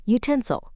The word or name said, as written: utensil